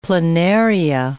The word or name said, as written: planaria